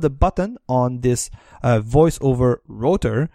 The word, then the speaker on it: rotor: Router? – Router? – Yeah, no, rotor, like ROTOR. Sorry for the pronunciation.